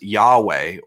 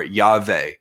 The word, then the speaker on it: Yahweh: In fact, the way I learned to pronounce the name when I took Hebrew in graduate school was Yahweh or Yahweh.